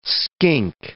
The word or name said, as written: skink